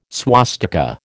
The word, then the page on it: swastika: American English